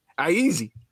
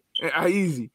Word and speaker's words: Iezzi: Make sure you check that out from fabulous writers, Ashley Woods and AJ Iezzi. Is that how you say it? – Iezzi. – I like that. Iezzi.